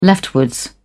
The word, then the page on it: leftward: British English